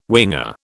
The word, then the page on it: winger: British English